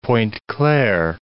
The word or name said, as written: Pointe-Claire